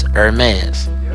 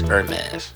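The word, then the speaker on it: Hermès: And it's not Hermes, it's Hermes. – Message. – Now, a lot of motherfuckers be, give me that Hermes. No, it's Hermes. Well, the H is silent.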